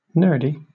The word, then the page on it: nerdy: British English